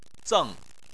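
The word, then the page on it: zeng: tone 4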